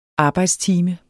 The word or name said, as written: arbejdstime